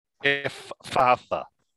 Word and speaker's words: Ephphatha: Ephphatha.